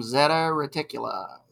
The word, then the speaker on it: Zeta Reticuli: And it is a very detailed story about an alien exchange program with humans in the star system Zeta Reticula. Did I say that right? – Or is that yeah, right? That's it.